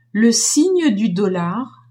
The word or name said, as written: signe du dollar